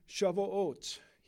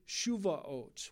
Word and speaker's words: Shavuot: It's on Shavuot here. I know some pronounce it Shuvaot, with more of a sound, a shoe sound at the beginning.